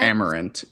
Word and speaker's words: Amerant: Amerant, yeah.